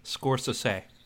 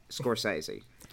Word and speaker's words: Scorsese: Is it pronounced Scorsese or Scorsese? – Scorsese. – Okay. – It's Scorsese.